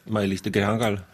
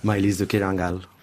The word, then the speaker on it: Maïlis de Kerangal: Et comment prononce-t-on « Maïlis de Kerangal » en finnois ? – « Maïlis de Kerangal ». – Ah oui, très simplement. Catalan, pareil. – Maïlis de Kerangal.